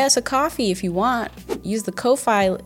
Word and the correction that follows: Ko-fi: No, so apparently it's not Ko-Fi. I think someone in the comments corrected me and they were like, I thought it was still pronounced coffee.